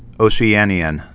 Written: Oceanian

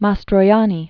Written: Marcello Mastroianni

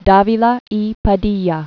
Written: Agustin Davila y Padilla

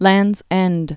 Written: Land's End

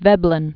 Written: Veblen

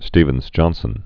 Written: Stevens-Johnson syndrome

